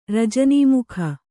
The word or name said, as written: ರಜನೀಮುಖ